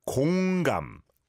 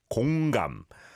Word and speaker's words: 공감: Again, almost sounds like 건강, but it's 공감, 공감.